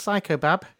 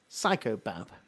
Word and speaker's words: Saicobab: That's Psychobab, which is how I've decided that that's pronounced. – It's certainly not spelt as you would imagine Psychobab is. – S-A-I-C-O-B-A-B. Psychobab.